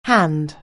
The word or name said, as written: hand